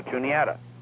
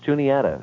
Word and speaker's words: Juniata: And then right out of Altoona, there's another one, J-U-A-N-I-T-A. And she says, oh, Juanita. I said, no, Juniata. – Yeah, it is pronounced Juniata.